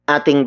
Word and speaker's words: ating: As a possession indicator, a good example would be ating bakuran. Ating bakuran. Ating bakuran.